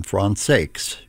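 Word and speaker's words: Françaix: WRCJ in Detroit, in the music of Jean Francais, or John Francakes, if you want to pronounce it that way. I understand that the family pronounced that final X in his name.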